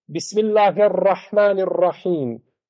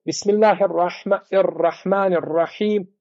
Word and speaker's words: Bismillahirrahmanirrahim: Pa kada uči čovjek u teđvidu je pogrešno da kažeš Bismillahirrahmanirrahim. Ovo tikanje jezika je šta? Ispravno. To je tikrar, to je znači ponavljaj. Ti izgovore Bismillahirrahmanirrahim. Izgovorio s tri puta slovo ra. A ima u Kur'anu koliko? Dva. Odnosno jedno sa teštivom, je li tako? A ti izgovore nekoliko puta, to je pogrešno.